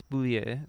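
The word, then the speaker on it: Bulleit: Yeah, it's not bullet. It's bouillet. So all you fucking weirdos out there are pronouncing it wrong.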